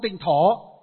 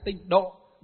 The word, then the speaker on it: tịnh độ: Nhưng mà thực tế thì trong Phật giáo chúng ta đọc là đọc trại đi là tịnh độ. Độ là cõi nước. Thổ là gì? Là cõi đất. Nhưng đây chúng ta hiểu cõi nước. Cõi nước của Đức Phật A-di-đà là tịnh độ tông.